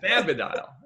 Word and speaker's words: cannabidiol: I think we pronounce it cannabidiol or, you know, different ways. – Yeah, cannabidiol. it's cannabidiol.